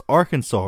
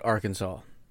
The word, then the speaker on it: Arkansas: All my life I've been taught Arkansas. And sometimes, being silly, I'll say Arkansas. – Arkansas, that's what it looks like.